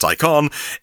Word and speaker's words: Taycan: It's now called the Taycan, spelt Taycan, but pronounced Taycan.